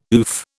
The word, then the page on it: oof: British English